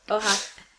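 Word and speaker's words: Oha (Оха): Okha. Am I saying it right? – No. Oha.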